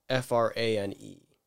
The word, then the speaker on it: Frane: So I want to know if you've ever heard of the guy by the name of Fran Selleck. – I haven't heard him. – Fran Selleck. I forget. I don't know how you pronounce it, but Fran, like the nanny. – Frane.